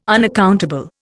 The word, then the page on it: unaccountable: Indian